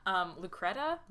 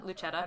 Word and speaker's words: Lucetta: Lucretta? – Lucetta? – Lucetta.